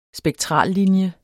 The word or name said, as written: spektrallinje